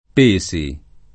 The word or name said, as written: Pesi